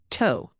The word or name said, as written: toe